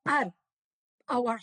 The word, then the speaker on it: Haro: And two of the creditors of this company in France were the owners of a building in Arr, our hometown where now the winery is based. – How do you say it? Haro. – Arro. H-Arro. – Yeah, Arro.